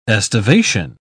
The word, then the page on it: aestivation: English; British English